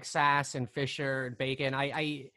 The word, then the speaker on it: Sasse: Ben Sasse, yep.